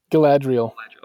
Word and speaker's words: Galadriel: So you have Kate Blanchett as a, how do you pronounce, is it Galadriel? – Galadriel.